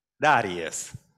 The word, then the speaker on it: Darius: How do you pronounce this one? Darius.